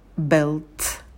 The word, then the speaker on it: built: «Build», «built», «built». В первом глаголе «д» звонкий, а потом «т» с придыханием.